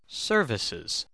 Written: services

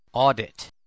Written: audit

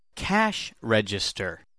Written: cash register

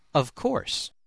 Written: of course

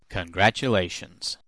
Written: congratulations

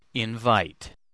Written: invite

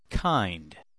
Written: kind

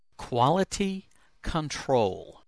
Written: quality control

